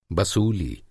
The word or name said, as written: बसूली